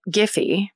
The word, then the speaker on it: Giffey: And 35-year-old ex-biology professor turned real estate agent George Giffey Jr. So the spelling of this is G-I-F-F-E. – Hmm. – So I was reading it, pronouncing it Giffey. – Yeah. – Could be GIF. – Then why would they put the E at the end? I think you're right with Giffey.